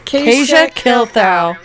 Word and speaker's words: Kasia Kilthow: My name is pronounced Kasia Kilthow. – Kasia Kilthow. Well, it's a good thing somebody fucking said something, because we'd have fucked that up forever. – I know. I was like, just send it in a voice clip. I got you. And then I still said it wrong.